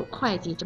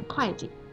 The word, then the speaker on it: 会计: 会的用法确实有点多，不过快就好记了，因为快基本就只有会计这么一个用法，所以以后再看见会计，可别念成会计了啊。